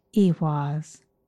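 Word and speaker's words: Ehwaz: Its name is pronounced Ewaz, and it means horse or two horses, which are symbolic of transportation.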